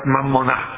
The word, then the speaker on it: mammonà: In italiano non vuol dire niente, è una parola ebraica, mammonà, sarebbe con l'accento finale, che è il patrimonio, la roba, le cose che fanno parte delle mie ricchezze.